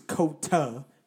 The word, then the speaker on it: Kota: K-O-D-O. – Okay, this is Kota. K-O-T-A.